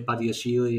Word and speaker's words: Badiashile: Badiashile, yeah.